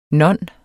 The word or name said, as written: non